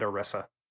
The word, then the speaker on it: Orisa: We got a Bastion rework and an Orisa rework. – Orisa? – One of which, shoot, did I say the name wrong? – You said Orisa.